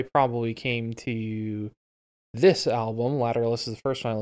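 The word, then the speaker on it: Lateralus: It turns out they pronounce it Lateralis.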